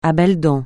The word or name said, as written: à belles dents